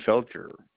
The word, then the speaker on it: Felcher: F-E-L-C-H-E-R. – Okay. Spell that again. F-E-L. – F-E-L-C-H-E-R. – Okay. And kind of like cheer.